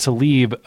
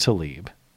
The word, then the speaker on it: Tlaib: Rich Ben says, Tlaib is pronounced Tlaib. Oh, so it's pronounced Tlaib. Gotcha. Just like Favre isn't pronounced like it's spelled, but well, thanks for the tip.